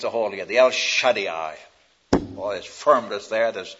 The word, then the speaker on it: El Shaddai: the El Shaddai. I suppose you want me to spell that for you. All right, E-L. S-H-A-D-D-A-I, double D-A-I. E-L-S-H-A-D-D-A-I, El Shaddai.